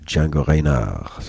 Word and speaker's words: Django Reinhardt: Det här är från en skiva som heter Django Reinhardt, eller Django Reinhardt som det egentligen ska uttalas, And the Singers with Jean Sablon.